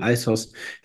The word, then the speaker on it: ISOS: Und die haben so ein ISOS, also International SOS, wird abgekürzt ISOS und wird im Englischen ausgesprochen ISOS. ISOS hat insgesamt, ich meine, 12.000 Mitarbeiter weltweit.